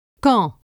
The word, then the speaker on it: quand: Quand. Quatre. Québec. Physique. – Only when QU precedes OI is it pronounced que. Pourquoi? In liaison, the D of quand is pronounced T.